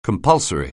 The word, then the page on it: compulsory: anglais